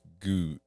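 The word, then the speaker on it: Gose: That's how you say it. Goose.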